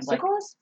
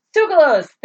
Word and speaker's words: Tsoukalos: I love George Tsitsakis. I think that's how you say his name. – Tsoukalos? – Tsoukalos! Thank you.